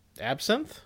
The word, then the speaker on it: absinthe: Absinthe? – Absinthe, yes.